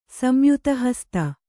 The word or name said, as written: ಸಂಯುತಹಸ್ತ